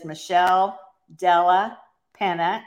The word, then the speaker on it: Michelle Della Penna: My guest today is Michelle Della Penna.